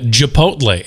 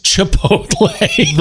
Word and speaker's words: Chipotle: And I also think you just called it Chipotle. It's Chipotle.